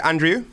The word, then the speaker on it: Andreou: Andreou.